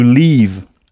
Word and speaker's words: leave: Il faut bien prolonger le son to leave à ne pas confondre avec to live, avec un I court qui s'écrit LIVE, qui veut dire vivre, habiter.